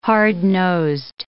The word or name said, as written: hard-nosed